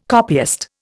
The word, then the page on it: copyist: Indian